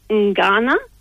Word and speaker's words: Ngana: So we have three sessions on the Sunday, and one of them, the first one is, I've got to pronounce this correctly because I've been schooled in how to pronounce this in the Wadawurrung language. It is Ngana.